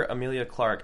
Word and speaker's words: Emilia Clarke: it's so cool to watch a younger Emilia Clarke.